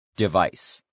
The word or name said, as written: device